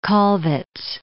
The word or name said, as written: Kollwitz